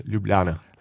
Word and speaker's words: Ljubljana: I sure enjoyed my visit with you last summer in your beautiful capital, Ljubljana. – That's right. – Did I say it right? Say it again. – Yes, Ljubljana. – Ljubljana.